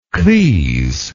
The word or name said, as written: crise